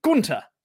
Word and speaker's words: Gunther: Therefore, here I am once again to remind everyone that Gunther does not have a TH in the name. The H is silent. Yes, it is technically Gunther.